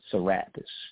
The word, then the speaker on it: Serapis: So you fuse them together, and you have what the Greeks stole as Serapis, some like to pronounce it Serapis.